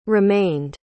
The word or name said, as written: remained